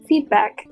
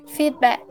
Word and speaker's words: feedback: Então, pode nos mostrar a maneira certa de pronúncia? Claro, feedback. Feedback. Feedback.